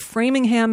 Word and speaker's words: Framingham: The city of Framingham, Massachusetts, and yes, that's how you pronounce it, just became a city.